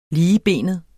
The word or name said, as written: ligebenet